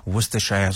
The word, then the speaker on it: Worcestershire: I can see my grandfather taking out the Worcestershire sauce. Did I say it right? Worcestershire sauce. – The first time was better than the second, yeah.